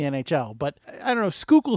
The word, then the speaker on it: Schuylkill: I cheated and looked it up how to pronounce that, and I think it's Schuylkill, so you're extremely close, but I like the way you pronounce it better anyway, so Schuylkill, that's how it's going to be for this podcast.